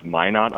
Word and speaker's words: Minot: It's pronounced Minot, I believe, Minot. But many of the locals say Minot.